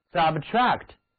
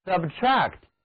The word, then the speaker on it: subtract: 接下来我们来给词根 tract 加一个表示在下面的前缀 SUB， sub，那么得到的新单词重音在后面一个音节上，读作 subtract， subtract。